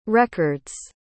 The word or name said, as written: records